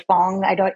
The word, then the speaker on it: Fang: And there's also a group of people called the Fangs or Fong. It's spelled F-A-N-G, but they have quite a bit of colonial French influence into their work.